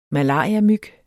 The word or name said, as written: malariamyg